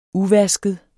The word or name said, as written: uvasket